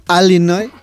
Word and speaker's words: Illinois: ¿Sabes que hay, como aquí en España, hay acentos diferentes en Estados Unidos y los nativos del estado de Illinois lo pronuncian Illinois?